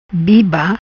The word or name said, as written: Biba